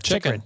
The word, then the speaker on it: Chychrun: How do you say that name? – Chychrun.